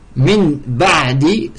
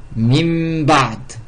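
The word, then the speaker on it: mim ba'd (مِنْ بَعْدِ): Min ba'di se prononce mim ba'd. Mim ba'di. Au lieu de dire min ba'di, le nun il est transformé en mim.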